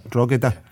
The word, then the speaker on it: Drogheda: I don't know if I pronounced that right. Drogheda, is it? – Drogheda.